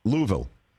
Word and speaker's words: Louisville: How do you say the city in Kentucky where they make bats? – Louisville. – Okay. That's what I say, Louisville. – You have to. It's not Louisville. That pisses them off.